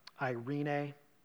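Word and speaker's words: irene (εἰρήνη): In Greek, it's pronounced irene.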